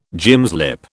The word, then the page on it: gymslip: British English